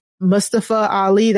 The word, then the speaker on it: Mustafa Ali: Again, Mustafa Ali, that's how you are supposed to say it.